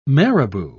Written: marabou

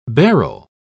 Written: barrel

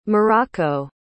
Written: muraco

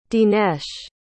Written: dinesh

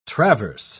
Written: traverse

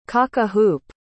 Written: cock-a-hoop

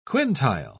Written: quintile

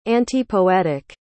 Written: antipoetic

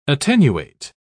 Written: attenuate

